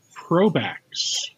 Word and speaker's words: Probax: Um, so, so you are with Probax. Yes. So I and I hope I said that right. It's like a portmanteau of pro and backups.